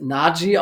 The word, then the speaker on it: Najee: Zum Beispiel Najee, das hat er erklärt, es wird Najee ausgesprochen, war ihm ganz wichtig.